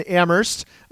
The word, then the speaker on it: Amherst: You don't pronounce the H in humble, just like you don't pronounce the H in Amherst, I guess.